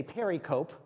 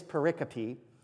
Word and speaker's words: pericope: I may accidentally say pericope, because that's how it's spelled, but it is pericope, which simply means a cutting out.